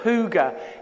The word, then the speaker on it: hygge: I don't know if you know it, at the moment it's kind of a little bit in, but there's this thing called hygge. It's spelled H-Y-G-G-E,